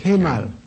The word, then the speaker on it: Kemal: How do you suppose we pronounce that, Mr. Kemal. – Kemal? Yeah.